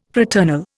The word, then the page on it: fraternal: Indian